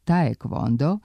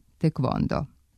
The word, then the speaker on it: taekwondo: A ako je pravopisno načelo da se u transkripciji polazi od izgovora imena u izvornom jeziku, nejasno je zašto su se pravopisci odlučili da korejski sport nazovu prema engleskom izgovoru taekwondo, a ne prema korejskom tekvondo.